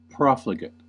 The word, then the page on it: profligate: American English; adjective; noun